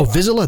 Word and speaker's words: Vizsla: Oh, Vizsla? That's how you say it. You say Vizsla. Yeah, that's the correct way.